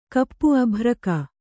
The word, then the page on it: ಅಬ್ಬರಿಗ: kannada